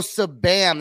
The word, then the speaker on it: Sabam: So Sabam, that's how you say it. It's not S-B-A-M, it's actually Sabam.